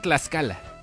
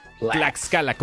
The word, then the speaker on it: Tlaxcala: Es que la gente dice Tlaxcala. Tlaxcala no existe, pero Tlaxcala, como Tuxtla.